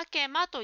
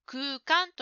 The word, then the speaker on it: 空間: それから答えの文の宇宙空間という言葉ですが、ジムさんは宇宙あけまと言っていました。この言葉は空間と読みますよ。